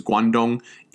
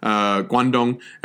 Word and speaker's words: Guangdong: Kwantung, K-W-A-N-T-U-N-G. The orthography of this name is kind of strange. What it is a Japanese pronunciation of the Chinese term for the general region. The Chinese pronunciation is Guangdong, east of the pass. East of the pass. Guangdong. And then the Japanese pronunciation of that is the Kanto, Kanto region.